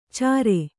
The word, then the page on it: ಛರು: kannada